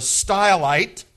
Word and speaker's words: Stylite: The Greek word for platform is stoule, which we would pronounce style. And so he was called Simon the Stylite, and all of his followers, were called Stylites.